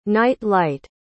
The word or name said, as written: night-light